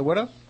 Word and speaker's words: Whydah: The Whydah.